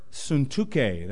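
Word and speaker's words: Syntyche: I implore Yodia, and I implore Suntuke," that's the Greek pronunciation of her word, of her name, "to be of the same mind in the Lord.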